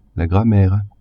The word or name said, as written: grammaire